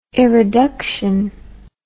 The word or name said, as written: eroduction